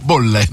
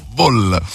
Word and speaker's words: ball: Bolle, no, bo, ball, non bolle, ball.